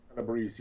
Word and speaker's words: Calabrese: Calabrese, here we go. I can't say nothing right. – No, you Calabrese, you said it right.